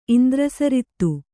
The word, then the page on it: ಇಂದ್ರಸರಿತ್ತು: Kannada